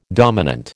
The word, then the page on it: dominant: American English